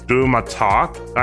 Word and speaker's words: DouxMatok: The Israeli startup Do- DouxMatok, I think it's pronounced, it's D O U X M A T O K that's how it's spelled.